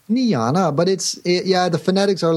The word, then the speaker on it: Niejna: So we went for the next best thing, N-I-E-J-N-A, which is how you pronounce Niana in Flemish.